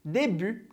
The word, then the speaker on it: début: Aqui em début, a gente tem o T, que não se pronuncia no final, e a gente também tem o som do U, aquele som do biquinho que a gente viu na primeira aula do mini-cours.